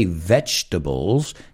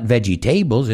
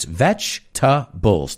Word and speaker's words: vegetables: Remember, if you can't say vegetables, say veggies. Because it's not vegetables, it's vegetables. Tres sílabas. Vegetables.